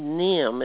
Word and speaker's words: Nahum: And the Koons family had an older son named Nim. It's spelled N-A-H-U-M.